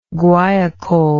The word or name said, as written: guaiacol